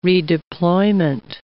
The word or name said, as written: redeployment